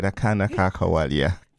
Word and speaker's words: Akana Ka Kawalia: That song you just heard was from the Afrigo band called Akana Ka Kawalia. Did I say that right? Akana Ka Kawalia. – Perfect.